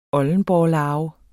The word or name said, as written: oldenborrelarve